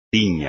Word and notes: tiña: espagnol

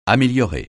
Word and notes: améliorer: francais

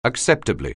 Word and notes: acceptably: anglais

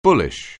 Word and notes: bullish: anglais

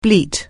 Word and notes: bleat: anglais

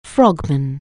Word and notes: frogman: anglais

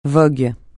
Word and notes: vogue: francais

